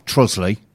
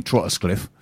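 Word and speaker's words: Trottiscliffe: Chief amongst them are Trosley, which she insists on calling Trottersclift.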